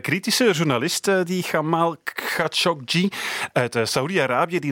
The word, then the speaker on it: Khashoggi: Dus misschien even oefenen. – Jamal Khashoggi. Khashoggi. Khashoggi. Khashoggi. Khashoggi. Khashoggi. Jamal Khashoggi. – zo is het.